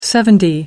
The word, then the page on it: seventy: American English